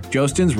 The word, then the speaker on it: Joostens: Joostens Roofing will keep you dry and leak free all year long. Spelled like Justins. Visit J O O S T E N S.